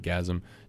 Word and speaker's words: Ghazm: Gazm. If you're just yeah. tuning in, I'll say it again. The band is called Gazm, G-H-A-Z-M, or G-H-A-Z-M, if you want to just cut to the chase.